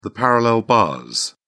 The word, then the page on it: parallel bars: British English